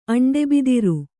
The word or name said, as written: ಅಂಡೆಬಿದಿರು